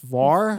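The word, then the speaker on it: Voir: But what he's doing is a new series of short documentaries that he's calling, I guess it's pronounced VAR. – VAR, yeah. – It's like noir, but with a V.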